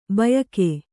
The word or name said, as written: ಭೂಯುಗ